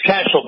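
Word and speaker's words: Cashill: And it's Cashel, by the way. Rhymes with Dashiell, our former...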